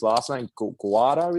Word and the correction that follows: Guardiola: is it? – Guardiola. – Guardiola.